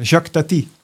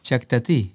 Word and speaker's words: Jacques Tati: Iets waar je volgens mij ook wel veel voeding uit gehaald hebt, is Jacques Tati. Zeg ik het goed? – Nee, je zegt het fout, maar Jacques Tati. – Jacques Tati.